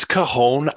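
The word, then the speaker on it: cajón: And racquetball, volleyball, doing some musical things with the guitar and the cajon. Am I saying that right? – It's cajon.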